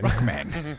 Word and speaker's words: Ruckman: Ruckman.